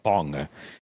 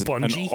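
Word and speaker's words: Bunge: Yeah, so dimethylmercury was first synthesized in 1858 by this dude called Alexander von Bung. Which is Bungie? – An awful name. I guess I could pronounce the E and call it Bungie, but I much prefer Bung.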